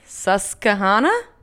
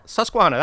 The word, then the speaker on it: Susquehanna: Susquehanna? – Say it again. Try saying it again. – Am I right? – If we'll allow mispronunciations, you're correct. – Susquehanna. – Susquehanna. That's pretty good.